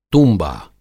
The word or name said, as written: तूँबा